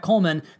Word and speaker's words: Kollmann: You can find him on Twitter at Brett Coleman. That's spelled K-O-L-L-M-A-N-N. It's that second N that will get you.